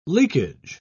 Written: leakage

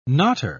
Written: knotter

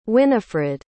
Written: Winifrid

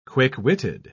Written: quick-witted

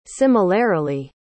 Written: similarily

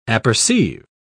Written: apperceive

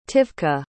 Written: tivka

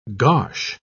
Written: gosh

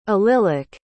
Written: allylic

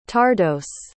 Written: tardos

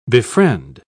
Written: befriend

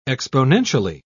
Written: exponentially

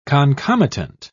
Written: concomitant

